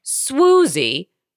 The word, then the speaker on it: Swoozie: She got her first name, Swoozie, which rhymes with Lucy rather than Woozy.